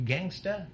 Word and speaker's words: Gangsta: Is that the way you're supposed to pronounce it? Gangsta? – Yeah. – But it's spelled as Gangs T-E-R.